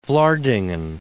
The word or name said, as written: Vlaardingen